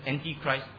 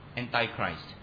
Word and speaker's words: Antichrist: Some of you want to pronounce it as Antichrist. It's fine. Just don't quarrel with me. Antichrist.